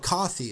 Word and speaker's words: Ko-fi: but it's called Ko-fi. It's spelled K-O-F-I.